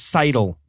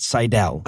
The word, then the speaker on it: Seidel: It's Seidel, by the way, not Seidel. – Oh, I thought it was. – Despite Seidel, what I'm like, about to say over and over again. – Like Eric Seidel, the poker player? – Maybe that's who I was.